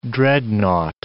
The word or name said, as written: dreadnought